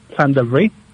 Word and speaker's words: Llandovery: Well, the English pronunciation is Llandovery. – Yep. – And then the Welsh is Llanymddyfri.